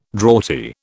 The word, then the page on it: draughty: British English